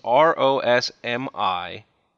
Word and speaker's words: Rosmi: No, it's it's R O S M I. And then H-I-S. – R-O-S-M-I? – Uh-huh. – Rosmi. – Rosmi. And then Hisham. H-I-S-H-A-M.